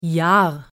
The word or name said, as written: Jahr